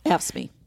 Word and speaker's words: AFSCME: AFSCME.